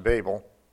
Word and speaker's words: Babel: Babel, I've heard it pronounced Babel, but that was in a British television show, so I figured it just because they're British. But then I looked at my Thompson Chain Reference Bible, which has that self-pronouncing text, and it actually is pronounced Babel there, so I guess it could be pronounced that way as well.